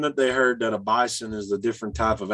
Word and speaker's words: Bison: They want to pronounce their name Bison with a Z, even though it's not spelled that way.